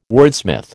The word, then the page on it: wordsmith: American English